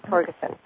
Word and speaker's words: Torgerson: Torgerson.